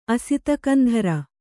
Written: ಅಸಿತಕಂಧರ

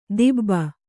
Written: ದಿಬ್ಬ

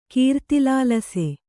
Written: ಕೀರ್ತಿಲಾಲಸೆ

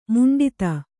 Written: ಮಂಡಿತ

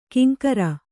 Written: ಕಿಂಕರ

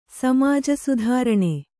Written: ಸಮಾಜಸುಧಾರಣೆ

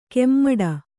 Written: ಕೆಮ್ಮಡ

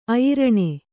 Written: ಅಯ್ರಣೆ

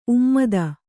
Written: ಉಮ್ಮದ

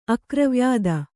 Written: ಅಕ್ರವ್ಯಾದ

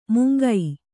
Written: ಮೂಂಕು

